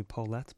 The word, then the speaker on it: Polat: P-O-L-A-T. The Australians growing up call me Polat, but it's Polat.